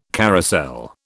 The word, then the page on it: carousel: British English